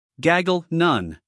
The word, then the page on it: gaggle: ˈɡæɡl